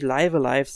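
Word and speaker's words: Live A Live: aber es müsste, also die offizielle Aussprache müsste tatsächlich Live Alive sein, weil das Spiel im Japanischen auch Raibu Araibu geschrieben wird und danach kann man eigentlich immer gehen,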